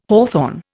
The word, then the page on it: hawthorn: Indian